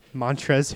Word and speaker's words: Montrezl: Montrezl Harrell? I think it's a silent L on the end, man.